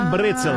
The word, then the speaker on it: Brezel: É o pretzel, mas a pronúncia correta é Brezel.